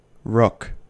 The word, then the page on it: rook: American English